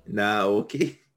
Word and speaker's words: Naoki: Naoki? Naoki. – Naoki. – Sano. – Sano. Naoki Sano. – You're really Americanizing it.